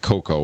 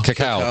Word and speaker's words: cacao: Not cocoa. What is it? How do you say the other one? – Cacao.